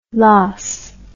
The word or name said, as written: los